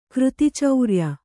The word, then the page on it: ಕೃತಿಚೌರ್ಯ: křti caurya